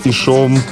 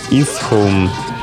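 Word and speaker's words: Ishome: Поэтому оно произносится не как «ишом», как некоторые его говорят, а «исхом», так как Мира сама этого захотела.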